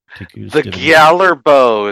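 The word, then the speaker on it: Gjallarhorn: The Gjallar bow is how you say that.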